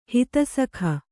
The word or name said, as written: ಹಿತಸಖ